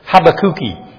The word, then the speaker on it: Habakkuk: They said, yes, they pronounce it Habakkuki. Sounds like have a cookie, but Habakkuki, that's Portuguese for Habakkuk or Habakkuk.